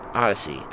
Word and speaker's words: Oddisee: Odyssey. I don't know. It's like O D D I S E E. – Odyssey. – Odyssey. But it's not spelled like Odyssey.